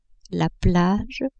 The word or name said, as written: plage